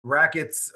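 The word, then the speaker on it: Raqts: I see Rackets, of course. I assume that's pronounced just like Rackets, even though it's R-A-Q-T-S.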